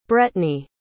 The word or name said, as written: brettney